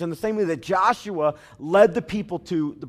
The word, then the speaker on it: Joshua: It's pronounced Joshua in the Hebrew language and Jesus in the Aramaic.